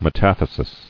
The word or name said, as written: Metatheses